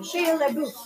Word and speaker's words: Shia LaBeouf: Shia LaBeouf. Is that how you say his name? – Mm-hmm.